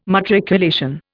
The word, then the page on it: matriculation: Indian